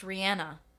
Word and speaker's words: Rihanna: I recently read that Rihanna is pronounced Rihanna. – No. – And we're all saying it wrong.